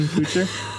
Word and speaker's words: Kutcher: Ashton Kutcher.